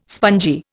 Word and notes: spongy: Indian

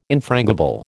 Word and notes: infrangible: American English

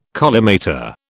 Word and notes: collimator: British English

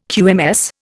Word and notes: QMS: Indian

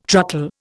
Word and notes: truckle: Indian

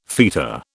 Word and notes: feta: British English

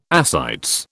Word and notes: ascites: British English

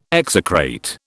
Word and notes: execrate: British English

